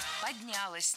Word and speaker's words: поднялось: Как это предприятие поднялось? Как там развилось? Когда его двигали там с одного предприятия на комплекс предприятий. – Поднялось неверно. Правильно, поднялось. Ударяем окончание. Развилось тоже не лучший вариант.